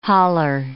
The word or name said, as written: haler